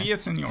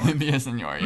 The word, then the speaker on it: Villaseñor: Villaseñor. – Villaseñor, yeah.